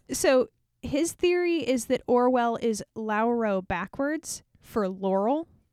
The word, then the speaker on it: Orual: And to make it very clear, we pronounce it Orwell here. – Yeah. There is no consensus on the main character's name. – To be clear, it is spelled O-R-U-A-L.